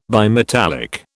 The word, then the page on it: bimetallic: British English